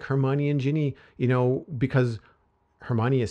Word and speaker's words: Ginny: or it is Ginny, right? That's how you pronounce it? – Ginny, yeah. Yeah.